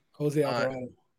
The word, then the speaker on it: Jose Alvarado: Jose Alvarado.